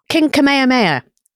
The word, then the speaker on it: Kamehameha: Am I pronouncing him right? Kamehameha? – King Kamehameha. It's King Kamehameha who is...